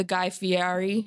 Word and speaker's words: Guy Fieri: I obviously don't remember the first time I tried an egg, but I'm like the Guy Fieri.